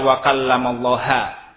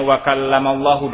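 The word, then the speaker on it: wa kallamallahu (وَكَلَّمَ اللَّهُ): Bidah harokat wa kallamallahu dibaca wa kallamallaha. Rusak kalimat. Rusak akidah kaum muslimin. Apa? Tujuan mereka merubah harokat saja. Jadi salah harokat, salah kalimat. Fatal akibatnya. Jadi kalau dibacanya wa kallamallahu dan ini yang benar, wa kallamallahu Musa taklima, dan Allah itu mengajak bicara Musa.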